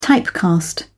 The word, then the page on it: typecast: British English